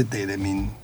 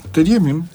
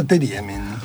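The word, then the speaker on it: Theremin: A mí, me los nombra todo el tiempo, y la verdad es que me acuerdo solamente de Teremin. Que tampoco se pronuncia así, por lo que vi. – Teriemin. – Ah, Teriemin.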